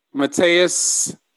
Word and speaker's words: Matheus: Striker, Mateus Ayas. Am I saying that right? – Nailed it.